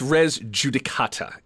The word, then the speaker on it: res judicata: I mispronounced that. – Yes. – Clearly, I've never heard it. It's res judicata.